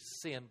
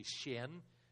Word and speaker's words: Shin: That Emma, she has already shared her testimony in Macau, and not too many days from now, Joey Shin, her name is, we say in English, Sin, but actually in Mandarin Chinese, you say Shin, is how you say it in Chinese.